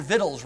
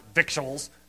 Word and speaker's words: victuals: I think, you know, the Beverly Hillbillies, they had their victuals, right? King James only word. Some people pronounce it victuals. Come on, it's victuals.